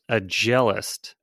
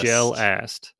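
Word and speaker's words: agelast: He radioed in and said that it's pronounced a jealist. – A jealous. – A gel-ast.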